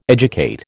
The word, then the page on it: educate: American English